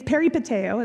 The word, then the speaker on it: peripateo: So this word walk here is a Greek word, and it means, it's peripateo is how you pronounce that lovely Greek word.